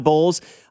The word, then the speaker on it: Bowles: I think Bowles it's Todd Bowles, right? – Yep. – Bowles. I didn't know if I said that correctly.